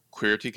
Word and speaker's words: Qweerty: And it was the original co-founder with me who decided on Qweerty Gamers, like two E's in the Qweerty keyboard. So phonetically, it sounds queer.